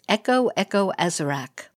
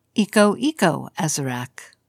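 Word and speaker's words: Eko Eko Azarak: Another chant Windblown Dedicates memorize is Echo Echo Azarac, or pronounced Eco Eco Azarac.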